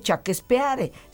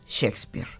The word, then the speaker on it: Shakespeare: Pero en realidad no se dice Chaquespeare, se dice Shakespeare.